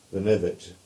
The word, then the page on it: Lanivet: English